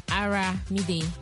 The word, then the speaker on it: Aramide: Mamba, je vous ai dit, c'est comme Olomide. Mais seulement c'est Aramide. Yeah. Voilà, exactement. Moi, j'ai prononcé ça en français. I was pronouncing in French, Armide.